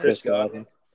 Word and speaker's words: Godwin: Chris Godwin.